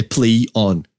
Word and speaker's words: PlayOn: Se escreve PlayOn, tudo junto, mas a pronúncia que eles querem é PlayOn mesmo.